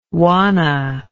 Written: juana

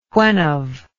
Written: whenve

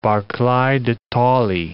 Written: Barclay de Tolly

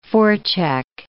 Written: forecheck

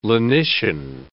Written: lenition

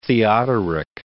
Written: Theodoric

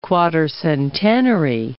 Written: quatercentenary

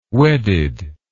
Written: wedded

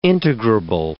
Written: integrable

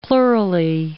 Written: plurally

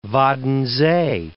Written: Waddenzee

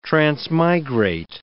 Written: transmigrate

